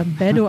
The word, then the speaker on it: Beto: Beto O'Rourke ist der demokratische Kandidat um das Gouverneursamt heißt aber eigentlich Beto, also eigentlich mit einem harten T.